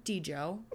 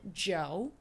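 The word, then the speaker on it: Djo: And it's because of Stranger Things, but also mainly because of his music, which I'm annoyed because I've been calling him D-Jo for the longest time ever, but he apparently pronounces it Joe, which he even said in like an interview that that was stupid of him.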